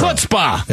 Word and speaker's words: chutzpah: Chutzpah. – Yeah, I believe it's pronounced.